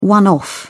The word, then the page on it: one off: British English